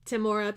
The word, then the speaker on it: Tamora: Did we decide that's how you pronounce her name? Tamora? – Apparently, yeah. – Tamora Pierce.